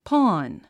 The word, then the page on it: pawn: pɔ́ːn; ポーン